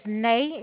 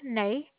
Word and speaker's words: Nea: is Nay. It is spelled NEA, Nay.